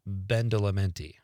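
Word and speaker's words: Badalamenti: I'm sure I'm saying that wrong, but yeah, Twin Peaks had great music. A great opening theme. And then Tyler says, correction, make that Angelo Bendelamenti.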